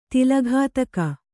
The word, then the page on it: ತಲೆಗುತ್ತುಗೆ: taleguttuge